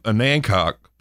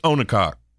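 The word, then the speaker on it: Onancock: Well, you might have noticed that they mentioned the town of Anancock, but they mispronounce it, which everybody who doesn't know better calls it Onacock.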